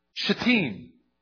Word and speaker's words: Shittim: And he said this: what happened from, this is a word you always want to pronounce very carefully in the Bible, Shittim.